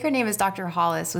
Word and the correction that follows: Hillis: with the CDC. You can correct me. Please correct me, Phil, if I've said her name wrong. – Yeah, Hillis. Susan Hillis. – Hillis. There it is.